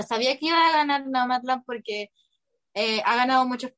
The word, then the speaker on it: Nomadland: no Nomadland. Ay, lo dije mal, parece. Eso. – No, está bien, está bien.